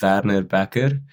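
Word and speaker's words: Werner Bekker: But so in Afrikaans, you'd pronounce it Werner Becker. But in English, I'd like sort of go between Werner, like the British people would say, and like Becker sounds good. I think it's sort of like a CK sound, but it's with two Ks. It's a bit hard. Werner Becker, I suppose.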